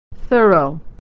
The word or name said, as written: thorough